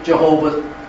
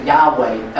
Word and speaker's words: Yahweh: Some pronounce it Jehovah. I prefer to pronounce it Yahweh as the Jews do.